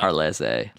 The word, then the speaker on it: Harleyse: Is it H-A-R-L-I-Z-E or H-A-R-L-E-Y-S? – It's that with an E at the end. – God. – Harleyse, like a caprese salad almost. You know, he actually tried Harleyse.